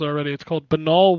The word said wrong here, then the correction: Balan: Wonder World, right? – Balan Wonder World, sorry. See, again, even I messed it up, and I knew that people had been messing it up.